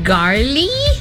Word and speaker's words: gnarly: And then there's also the word gnarly. – Gnarly. – Gnarly. – Yeah, the G is silent.